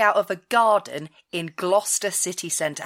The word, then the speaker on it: Gloucester: All I know about Gloucester is, for our international listeners who want to pronounce it Gloucester, just remember the rhyme. Dr. Foster went to Gloucester. Gloucester rhymes with Foster.